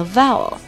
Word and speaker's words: vowel: vowel，vowel 这个单词啊，首先第一个音节啊是 vowel。 vowel vowel 就是跟誓言是一那个 vowel 是一个发音啊，vowel vowel，然后紧接着在 vowel 的后面加一个 a 的音，vowel vowel vowel，然后后面我们看到有个 l 啊，有个 l u 的卷舌，所以我们把它全部连起来就是 vowel vowel。